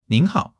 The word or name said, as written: nin2 hao3